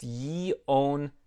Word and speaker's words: Jędrzejczyk: I had to practice that several times before speaking to her. It's Jędrzejczyk.